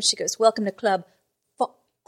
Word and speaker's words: Phoque: And in this one, they introduced the local strip club, which is called Club Fuck, but it's spelled P H O Q U E is how it's spelled.